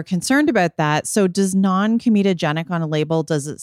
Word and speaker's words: non-comedogenic: Non-comedogenic.